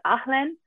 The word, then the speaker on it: haleine: so the closest I can say it is Ahlen, and it's spelled H-A-L-E-I-N-E, so a little different than me.